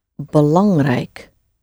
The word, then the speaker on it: belangrijk: The word for important is belangrijk. BELANG, belang. And then we have rijk at the end. RIJ, which is the I sound. K, rijk. Rijk, and there are more words ending in rijk, always get pronounced properly, the whole thing. Rijk doesn't get shortened in any way.